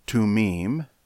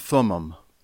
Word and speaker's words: Thummim: In David's time, after the original tabernacle, but before there was a Jerusalem temple, the ephod with the Urim and Tumim, again, you've probably pronounced those Urim and Thummim, but in the Hebrew it's Urim and Tumim, were hung up on the wall, then taken down and used for the purpose of discerning the Lord's will.